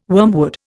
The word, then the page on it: wormwood: Indian